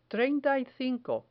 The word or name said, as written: treinta y cinco